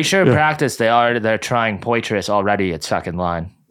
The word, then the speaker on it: Poitras: He's like Petri. Poitras, it's like a Petri dish. Yeah, some shit. – Poitras.